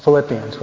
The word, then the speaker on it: Philippians: And it's the letter of St. Paul to the Filipinos. Did I mispronounce it? I guess it's the Philippians, right?